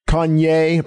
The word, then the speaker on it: Kanye: Kanye, pronounce that correctly.